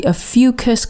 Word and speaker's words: Ophiuchus: I'm not sure if I'm getting the pronunciation of this 100% correct, but according to YouTube, it's the Ophiuchus constellation.